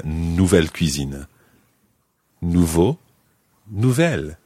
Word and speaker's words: nouveaux: Now, nouveau in the singular is spelled N-O-U-V-E-A-U, and in the plural, exactly the same, but with an X on the end. N-O-U-V-E-A-U-X. But it sounds exactly like the singular. Nouveau.